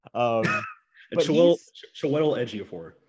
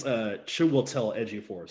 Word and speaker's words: Chiwetel Ejiofor: Chiwetel Ejiofor. – Okay. Thank you. Yeah. – Excuse me. I think it's Chiwetel Ejiofor.